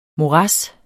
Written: morads

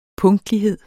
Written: punktlighed

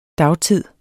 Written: dagtid

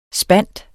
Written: spind

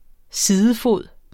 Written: sidefod

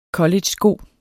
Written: collegesko

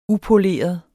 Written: upoleret

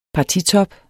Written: partitop

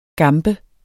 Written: gambe

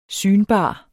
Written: synbar

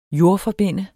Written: jordforbinde

